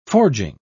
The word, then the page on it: forging: British English